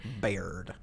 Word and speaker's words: Baird: I mean, it's really like the way in which Dr. Drew Baird gets transformed in the third season. Baird. – It's, I just like saying it. – Yeah. – You didn't say it wrong.